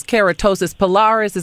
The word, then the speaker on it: keratosis pilaris: Now, see, Dr. James, now, just looking at this girl's, you know, little rash, I would have thought it was keratosis pilaris.